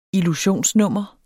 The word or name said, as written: illusionsnummer